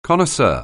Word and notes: connoisseur: anglais

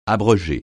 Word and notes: abroger: francais

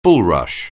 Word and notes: bulrush: anglais

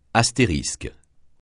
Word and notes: astérisque: francais

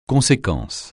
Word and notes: conséquence: francais

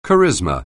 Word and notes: charisma: anglais

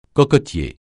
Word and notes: cocotier: francais

